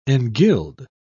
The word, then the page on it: engild: British English